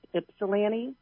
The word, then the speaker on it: Ypsilanti: I just wanted to tell you that it's actually pronounced Ypsilanti. – Ypsilanti, not Ypsilanti? – I know, Ypsilanti. – Come on, it's Ypsilanti. Okay, all right. I'll take your word for it.